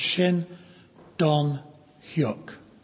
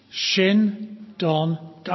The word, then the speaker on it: Shin Dong-hyuk: So I want to tell you a story about a man called Shin Dong-hyuk. Can you say his name? Shin Dong-hyuk.